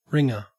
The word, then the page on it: wringer: Australian English